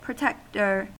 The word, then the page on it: protector: American English